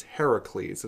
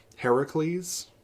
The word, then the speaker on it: Heracles: His name in the in the Roman is Hercules. His name is in the Greek is Heracles, and so you can hear the name Hera in there. Heracles.